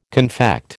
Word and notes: confect: American English

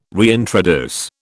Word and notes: reintroduce: British English

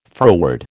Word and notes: froward: American English

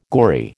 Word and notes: gory: American English

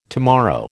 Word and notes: tomorrow: American English

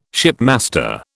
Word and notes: shipmaster: British English

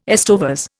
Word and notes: estovers: Indian

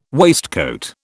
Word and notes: waistcoat: British English

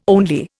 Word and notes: only: Indian

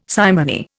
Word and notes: simony: Indian

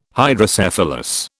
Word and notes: Hydrocephalus: British English

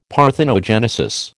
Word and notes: parthenogenesis: American English

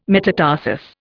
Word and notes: metatarsus: Indian

